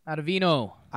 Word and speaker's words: Ottavino: If they're not interested in bringing back D-Rob, then is it Ottavino? Is that how you say it, Ottavino? – Ottavino. Hey, we're out of vino.